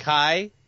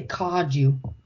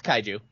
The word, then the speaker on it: kaiju: It's just kaiju. Kaiju. – I always say kaju. – Well, it's kaiju.